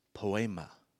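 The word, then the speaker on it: poiēma: The word there is pronounced poema, which you probably hear an English root word there, poem, right?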